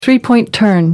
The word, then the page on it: three-point turn: American English